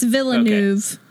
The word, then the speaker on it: Villeneuve: It's Villeneuve.